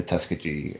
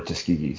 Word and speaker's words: Tuskegee: the Tuskegee Airmen. So, or Tuskegee, sorry.